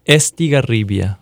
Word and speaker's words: Estigarribia: Ernesto, say your last name for me. – Estigarribia. – I cannot roll the R. – Well, it's not required.